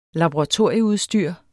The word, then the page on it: laboratorieudstyr: [ lɑboʁɑˈtoɐ̯ˀjə- ]; [ labo- ]